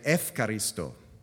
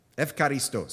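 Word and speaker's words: efkaristo (ευχαριστώ): Only the modern pronunciation has changed. Now they say efkaristo. Evcharisto. Say that with me.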